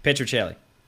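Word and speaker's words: Petruccelle: Lewis Jetta, Jack, and you're going to have to pronounce this one for me, but is it Petricelli? – Petricelli. – Oh, close enough.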